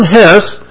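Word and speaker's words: Hesse: In Journey to the East, Hermann, is that pronounced Hess? Okay, Hermann Hess described the activities and relationships of explorers who were sent on a difficult mission by a certain order.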